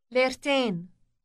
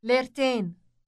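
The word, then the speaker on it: ليرتين: It sounds like English ten, but with an elongated vowel. Listen to the word for two lira. – Lertain. Lertain.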